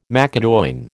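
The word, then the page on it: macédoine: American English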